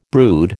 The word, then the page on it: brood: American English